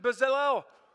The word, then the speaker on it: Bezalel: It says, Moses said to the Israelites, "See, the Lord has chosen Bezalel."